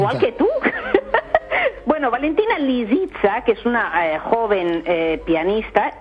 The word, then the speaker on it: Lisitsa: que está interpretada por Valentina Lisitsa, lo he dicho bien. – No, lo has dicho fatal. – A ver cómo es. – Pues hombre, Lisitsa. – ¿Lisitsa? – Lisitsa. Lisitsa, pues, con ese.